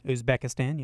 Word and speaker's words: Uzbekistan: And I think I pronounced this right. Uzbekistan? – Uzbekistan, yeah.